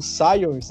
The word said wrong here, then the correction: Sawyers: Eu não sei como pronunciar o nome do meio-campista lá que saiu, que foi justamente pro Crash Brown. Crash Brown. – Ah, o, Sawyers. O meia.